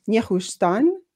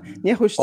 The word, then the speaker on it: Nehushtan: Nehushtan, did I pronounce it correctly? – Mm-hmm. – Uh-huh. – Nehushtan, all the weird enemy...